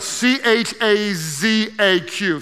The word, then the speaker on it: chazaq: It's spelled C H A Z A Q, chazaq.